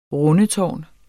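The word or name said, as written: RUNDETÅRN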